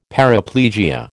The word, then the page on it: paraplegia: American English